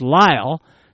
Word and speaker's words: Lisle: S-I-G-N-S. That's Signs. P.O. Box 122. Spell the town as L-I-S-L-E, pronounced Lyle.